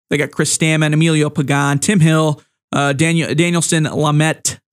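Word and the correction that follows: Lamet: LeMay, I believe is how you say his name, excuse me.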